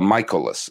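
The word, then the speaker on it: Mikolas: And the great thing about baseball, by the way, it's a Michaelis. That's how you pronounce it.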